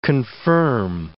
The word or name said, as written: confirm